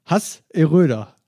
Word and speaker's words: Hasseröder: Und zwar haben wir das gute Hass Eröder. Hasseröder. – Ah ja, okay, so spricht man es ja.